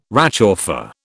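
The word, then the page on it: réchauffé: British English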